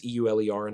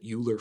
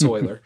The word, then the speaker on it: Euler: I mispronounced his name. It's Euler and I pronounced it Euler for the longest time. It's Euler.